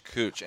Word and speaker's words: Kooch: K-O-O-C-H, Cooch.